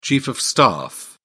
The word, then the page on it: chief of staff: British English